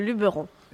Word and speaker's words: Luberon: Vous avez raison, c'est le Luberon.